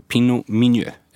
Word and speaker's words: Pinot Meunier: Det är Pinot Noir och det är Pinot Meunier. Eller uttalar jag det rätt? – Ja, du får godkänt från mig i alla fall.